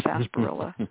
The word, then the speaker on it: sarsaparilla: There's another root that is called sarsaparilla, spelled sarsaparilla, but it really is pronounced sarsaparilla root.